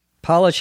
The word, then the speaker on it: polish: Notice also this word is spelled the same as Polish. Polish refers to someone from the country of Poland. Even though it has the same spelling, it has a different pronunciation when used as a verb, polish.